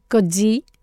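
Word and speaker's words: Coetzee: Κοντζή, συγγνώμη που τον προφέρω έτσι, αλλά είναι τόσο περίεργη η γραφή του που αναγκάστηκα να μπω στο YouTube, το κάνω αυτό, να δω πώς προφέρει ο ίδιος τον εαυτό του, αλλιώς δεν εμπιστεύομαι κανέναν.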